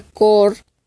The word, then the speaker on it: Chor: Ejemplo, se escribe chaos, pero vas a pronunciarlo como caos y la, la palabra chor se pronuncia cor, significa coro.